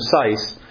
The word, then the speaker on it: Seiss: Well, is Sykes with a K or a T? – It's with an S. It's S-E-I-S-S. J.A. Seiss. – Spell it again. – S-E-I-S-S.